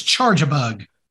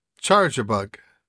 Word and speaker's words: Charjabug: I know this is your Pokemon, but did you just mispronounce the name of a Pokemon? – I don't think I ever said that. Oh, you did. Out loud before. You did. It's Charjabug. – Charjabug.